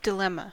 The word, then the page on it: dilemma: American English